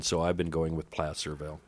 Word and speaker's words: Placerville: I'm going to pronounce it Placerville because I think placer is the type of mining, and you would pronounce that placer mining.